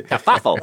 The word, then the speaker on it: kerfuffle: how do you pronounce that word? It causes a kerfluffle. – kerfuffle. – kerfuffle.